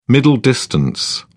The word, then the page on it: middle distance: British English